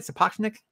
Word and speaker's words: Sapochnik: Sapochnik? – You got it right.